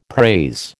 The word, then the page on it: praise: American English